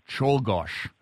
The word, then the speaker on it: Czolgosz: I believe I got this pronunciation right, Czolgosz. – Well, that's right.